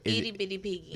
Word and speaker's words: Itty Bitty Piggy: Itty Bitty Piggy. – I don't know. – Did I say that right? – Itty Bitty Piggy. – There you go.